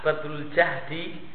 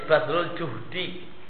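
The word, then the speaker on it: badlul juhdi (بذل الجهد): Ya, catatan kaki al-juhdi kalau jimnya domah maknanya tokoh, kemampuan, mengerahkan kemampuan. Kalau fathah badlul jahdi maka artinya adalah masyakok. Maka bacanya badlul juhdi, mengerahkan kemampuan, jangan jahdi.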